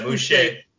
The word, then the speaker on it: Boucher: And then also, is it Boucher? Is that the right pronunciation? – Yeah, just like Bobby Boucher. – Yeah, Boucher.